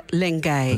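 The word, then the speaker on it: Lengyel: Eh, jullie hebben een lezing georganiseerd van Gabor Lenke, Lengai. Lengai, ja, Zeg ik het goed? – Ja.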